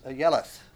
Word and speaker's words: Ayeleth: probably the best pronunciation of the word is ayeleth.